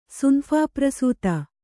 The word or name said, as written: ಸುನಫಾಪ್ರಸೂತ